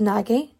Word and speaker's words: Nagy: Uh, how to pronounce Nagy? Okay, I'm gonna, I'm gonna go with the internet. It says his name is pronounced Nag Jakobet. Jakobet, Mrs. Jakobet. I will, I will say it correctly from now on.